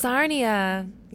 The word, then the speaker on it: Sarnia: Is it Sarnia? Is that Sarnia, yes. – It rhymes with Narnia.